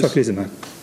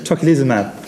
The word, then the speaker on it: Tocilizumab: Tocilizumab. – Say again? – Tocilizumab. – Tocilizumab. We're going to get this right. Tocilizumab.